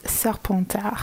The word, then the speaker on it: Serpentard: Hey, so the correct French pronunciation of the Hogwarts houses, we have Gryffindor, Ravenclaw, Sort d'Aigle, Hufflepuff, Pouf Souffle, and Slytherin, which is Serpentard.